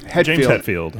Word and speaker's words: Hetfield: It's James Hetfield.